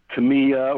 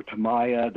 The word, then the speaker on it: Tamiya: and I got a bottle of Tamiya or Tamiya, depending on your pronunciation there.